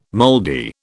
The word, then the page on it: moldy: British English